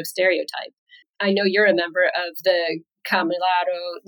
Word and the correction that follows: Kamilaroi: Nation. I'm sure I pronounced that wrong. – Yeah, Kamilaroi.